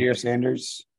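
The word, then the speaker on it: Shedeur Sanders: Shadur Sanders.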